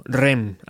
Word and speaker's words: R.E.M.: Hoy tenemos unos invitados de altura, Michael Stipe, Peter Buck, Mike Miles y Bill Berry, o lo que es lo mismo, REM, así es como lo pronunciamos en Spain.